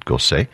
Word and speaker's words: Gossec: Last name is spelled G-O-S-S-E-C. One might think it's Gossec, but he apparently pronounced it Gosset.